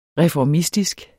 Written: reformistisk

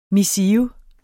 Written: missive

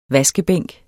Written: vaskebænk

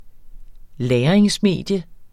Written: LAGRINGSMEDIE

